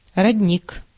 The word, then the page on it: родник: /rɐdʲˈnʲik/